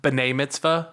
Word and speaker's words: B'nai mitzvah: And it's pronounced B'nai mitzvah? – Yeah. – Love that. – B apostrophe N-A-I mitzvah.